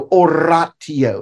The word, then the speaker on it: oratio: T-I-O is always, it's never sh, it's always T-I-O. So oratio is the word I gave you. Oratio, not oratio.